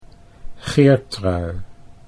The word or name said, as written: Geertrui